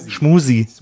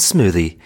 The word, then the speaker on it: smoothie: Ich finde, ihr solltet erstmal einfach Smoothie richtig aussprechen. Das wird mich schon freuen. – Schmusi. – It's a smoothie. It is a smoothie. Now repeat after me. Smoothie.